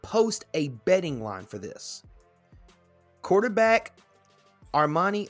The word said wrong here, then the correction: Armanti: I want to say that's how it's pronounced. Armanti, yeah, Armanti Edwards and the Mountaineers flum, flum, flummoxed it?